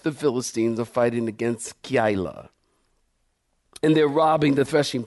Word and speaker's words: Keilah: Keilah is how you pronounce it.